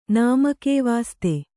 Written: ನಾಮಕೇವಾಸ್ತೆ

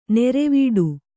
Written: ನಿರಿವಿಡಿ

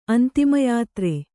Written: ಅಂತಿಮಯಾತ್ರೆ

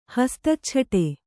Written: ಹಸ್ತಚ್ಛಟೆ

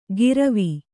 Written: ಗಿರವಿ

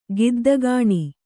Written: ಗುದ್ದುಗಣ್ಣು

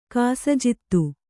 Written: ಕಾಸಜಿತ್ತು

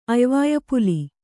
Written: ಅಯ್ವಾಯಪುಲಿ

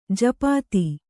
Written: ಜಪಿತ

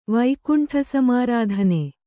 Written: ವೈಕುಂಠಸಮಾರಾಧನೆ

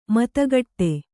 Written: ಮತಿಗಿಡು

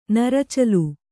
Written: ನರುಚಲು

